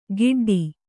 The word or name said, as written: ಗಿಡ್ಡಿ